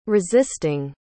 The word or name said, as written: resisting